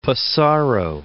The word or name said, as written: Pissarro